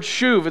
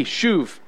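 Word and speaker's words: shuv (שוב): The Hebrew word for repent is the word shuv. It's spelled S-H-U-W-B, shuv.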